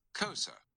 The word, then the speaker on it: Xhosa: It is spelled Xhosa. I'm going to click to listen to the pronunciation of that so we can all learn it together. – Kosa. – Apparently Kosa. It's Kosa. – Okay. – Yeah, that's fun to say. – It's a click.